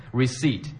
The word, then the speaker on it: receipt: これ、 receipt って、綴りは receipt、これ P 書きますけど、今、発音しましたっけ、 No, you don't pronounce the P. The P is silent. – なるほど。 P is silent とおっしゃいましたね。 silent 静か。まあ何も言わないっていう意味ですよね。 receipt P は発音しないそうです。